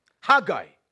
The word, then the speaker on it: Haggai: Haggai.